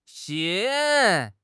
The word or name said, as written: 腥